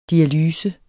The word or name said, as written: dialyse